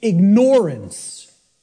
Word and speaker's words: ignorance: I tell people, start pronouncing it ignorance. Ignorance. Then people will hear the word ignore in it.